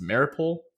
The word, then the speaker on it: Mariupol: So Russian forces have laid siege to, I believe it's pronounced Mariupol, yes.